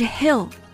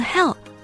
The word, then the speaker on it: hill: 对、发音在这里要注意，山或者是山坡是 hill， H-I-L-L hill，如果万一读错了成了 hell，那是另外一个地方。